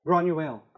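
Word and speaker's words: Granuaile: and I don't know how to pronounce her name, but Granuelle? – Granuelle.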